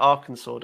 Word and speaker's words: Arkansas: I was just going to say, I'm surprised we haven't had more people comment about the way you say Arkansas. – What? But it says Arkansas on the thing. – I know, but they pronounce it Arkansas, don't they? – Is that correct? – It is correct, yeah.